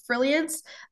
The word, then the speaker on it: Frilliance: And then my website and other pages is Frilliance. It's spelt like brilliance but with an F.